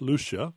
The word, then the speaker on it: Lucia: So, yeah, although I've asked, I think it was a Norwegian guy how to pronounce it, and he said, oh, Lucia. So I don't know what the true answer on that is. – Well, let me spell it again for listeners who may not have heard it before. Lucia, pronounced Lucia, Perillo, P-E-R-I-L-L-O, and her newest book is published by Copper Canyon Press called Inseminating the Elephant.